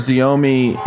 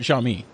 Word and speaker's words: Xiaomi: You know what's interesting is I wonder if Xiaomi and Samsung actually... – Oh, you're talking about Xiaomi.